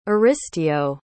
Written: aristeo

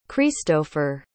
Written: cristopher

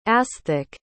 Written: asthik

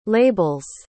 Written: label's

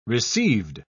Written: received

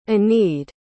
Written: enide

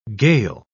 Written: gale

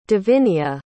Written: davinia

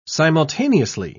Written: simultaneously

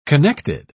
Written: connected

